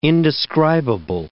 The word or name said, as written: indescribable